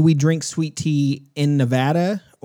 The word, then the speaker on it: Nevada: Since y'all are both from, one's from California, the other's from Nevada. – Yep. You get points for saying it correctly.